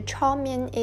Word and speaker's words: 炒面: The 炒面 is the Cantonese pronunciation of the Chinese characters above, which means stir-fried noodles.